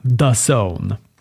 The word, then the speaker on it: DAZN: Då stod det “How to pronounce D-A-Z-N. the zone”.